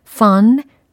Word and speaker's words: fun: fun, pun. 발음이 분명 다릅니다. 그쵸? 그래서 F 사운드와 P 사운드를 또 비교를 하는 시간입니다. fun, pun.